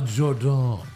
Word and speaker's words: Jordynne: It's pronounced a Jordan.